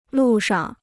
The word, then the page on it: 路上: lù shang